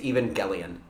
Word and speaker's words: Evangelion: Is that how it's actually pronounced, not Evangelion? – Yeah, it's There's Evangelion. No J, yeah. – It's Evangelion. – Yeah. Eva units, Evangelion. – Yeah. – Well, I would pronounce it like you would Angel. – That's and it. You're off the... Pack your shit and go. – No. – You're done. – They pronounce hard G.